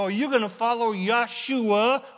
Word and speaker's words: Yeshua: Parenthetically, Joshua is pronounced Yeshua in Hebrew.